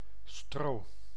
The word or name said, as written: paille